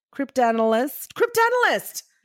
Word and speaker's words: cryptanalyst: Elizabeth Friedman, wife, mother, writer, Shakespeare enthusiast, cryptanalyst. – Oh, almost. Give it another go. – Cryptanalyst. Yeah. That's how you say it.